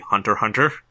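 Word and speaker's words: Hunter × Hunter: I believe the official pronunciation is actually Hunter Hunter. – Hunter? Okay, fuck that. – I think Hunter, everyone Hunter. I know calls it Hunter x Hunter, but like I think officially it's supposed to be pronounced Hunter Hunter.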